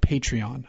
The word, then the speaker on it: Patreon: I'm curious about a little experiment I want to try with this podcast and another website called Patreon. I think that's how you pronounce it. – Yes, it is.